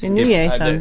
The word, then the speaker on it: Inouye: Apparently, the suspect, Scott Michael Inouye, how do you pronounce that? – It's like the mayor. – Sounds perfect. – Inouye sounds perfect.